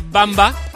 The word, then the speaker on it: Wamba: Wamba. – Exacto, en Valladolid, sí señor. Yo no lo sabía. De hecho se dice Bamba, pero se escribe Wamba, en honor a, es por el Rey Godó.